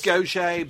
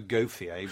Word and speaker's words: Gauthier: It is Gaucher, but spelt Gauthier. So you might naturally just go Gauthier, but it's not Gauthier.